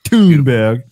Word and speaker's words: Thunberg: Yeah, say it right. It's Thunberg.